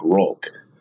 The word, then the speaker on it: Hulk: But in Portuguese, the H is pronounced with the R, right? With the R sound. So that's where it looks like Rolk with everything. So everyone started calling me Rolk.